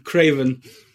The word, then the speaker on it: Craven: Craven.